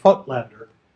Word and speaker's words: Voigtländer: He means by that a lens invented by a German optical company called Petzval and Voigtländer, generally referred to as the Voigtländer, Voigtländer, Voigtländer is how it's spelled, Voigtländer lens.